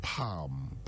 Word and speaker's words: palm: Same with palm. Palm. It's not palm.